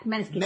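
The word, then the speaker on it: Måneskin: Yeah, Maneskin.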